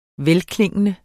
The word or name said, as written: velklingende